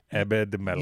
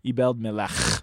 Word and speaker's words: Ebed-Melech: And if Thomas Smith is listening to this, he's the one that taught me how to correctly pronounce the Hebrew letter. But you have to act like a cat who's hacking up a hairball. Ebed-Melech.